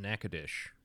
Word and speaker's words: Natchitoches: I mean, and Moxie, in the introduction that you wrote for us, you mentioned the incident in Natchitoches, which is how that town is actually pronounced,